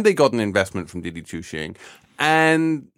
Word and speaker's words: Didi Chuxing: Didi Chuxing. Did I actually get the pronunciation? – Pretty much.